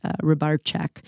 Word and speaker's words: Rybarczyk: Poland, my husband is Polish, hence the name Rybarczyk. And actually, when we lived in Prague, nobody struggled with our last name. And if we were actually still living in Europe, it would technically be pronounced Rybarczyk.